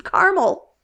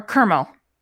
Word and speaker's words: caramel: See, there's also variations of how you say it too, because we say it as three, you say it as kind of like two, you say it as caramel or kermel.